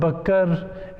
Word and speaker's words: Bakr: Abu, it's actually Bakr. We pronounce it Bakr. It's actually Abu Bakr.